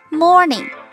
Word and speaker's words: morning: M O R N I N G morning 这个单词发音的时候，稍微卷一下舌头，好，放轻松，合起来再读一遍。